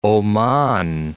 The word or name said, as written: Oman